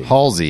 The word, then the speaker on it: Halsey: I've always said Halsey. – Halsey, yeah, that's what it is.